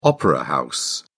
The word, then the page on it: opera house: British English